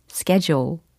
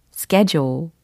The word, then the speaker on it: schedule: 영어 발음으로는 schedule, schedule 혹은 schedule 이렇게도 영국식 발음까지 다 섭렵하고 계시죠?